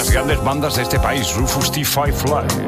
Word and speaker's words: Rufus T. Firefly: Claro, claro, yo lo he dicho mal. – Claro, y él solo quería decir. – ¿Cómo se dice? – Rufus T. Firefly.